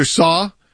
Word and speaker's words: CAO: Which, by the way, that's how we say Pastor Saw, but it is spelled CAO. So I want to make sure people understand that when they're going, why am I going to CAO? You may not have ever seen it in text. That's how it is spelled.